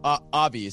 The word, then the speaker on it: Avi: Avi, is that how you pronounce it? – Yes.